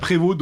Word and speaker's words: Prévost: Prévost, n'est-ce pas ? – Prévost. – Qu'on peut prononcer Prévost à la française.